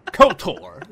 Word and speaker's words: KOTOR: Don't say it like that. KOTOR.